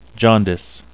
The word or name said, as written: jaundice